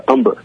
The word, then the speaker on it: Umber: It's Umber, like lumber.